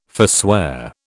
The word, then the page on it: forswear: British English